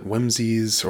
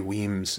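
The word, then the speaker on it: Wemyss: First one, I pronounced Lady Weems Jig wrong. I called it Whimsies or Wemyses. Thanks to Pete Stewart for pointing this out, but yeah, Lady Weems Jig.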